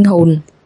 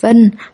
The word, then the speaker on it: hồn: Wan có nghĩa là linh hồn. Cô nói tiếng Anh như vậy. Anh không hiểu. Cô nói. Chữ này không đọc là vân, đọc là hồn, hồn trong linh hồn.